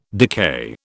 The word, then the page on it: decay: British English